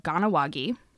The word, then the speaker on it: Kahnawake: And I know how to pronounce it because the video. – Nice. – But I would not have thought it was that. It's spelt K-A-H-N-A-W-A-K-E.